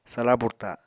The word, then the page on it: sala burta: apatani